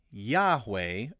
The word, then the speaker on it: Yahweh: The J was pronounced as the English letter Y. In Latin and Greek, it was represented by the letter I. So technically, you say Yahweh and Yehovah. The hard J sound came later when the Bible was translated into French and English.